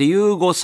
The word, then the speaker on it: YUGO: そして、アルファベットで YUGO と書いて、ゆうごさんとは、リモートでつながっております。